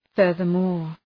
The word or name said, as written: furthermore